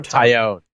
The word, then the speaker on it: Taillon: Is it Taillon or Taillon? – Taillon.